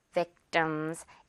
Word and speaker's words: victims: Victims. 이렇게 뜸, 밥 뜸들이듯이 뜸 발음으로 가보겠습니다.